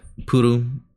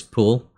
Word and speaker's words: Puru: And while that is discussed in various Gundam, it's Unicorn that they specifically expand on Puru, the character from Double Zeta. Sometimes in English, it's easy to pronounce it as Pull.